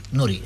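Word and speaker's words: Noury: credo si pronunci Nuri, non so come si pronuncia, Riccardo Nuri, Nuri, esatto, è così.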